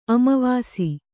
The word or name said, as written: ಅಮಾವಸೆ